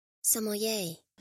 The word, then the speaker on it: sommelier: When Sylvia holds Lewis accountable for giving Elijah a hard time, about his interest in being a sommelier. Oh my gosh, I totally butchered that. I'm gonna just have Skynet say like filling in every time I'm saying it. So that's who's gonna be talking because apparently I cannot get this word. Sommelier.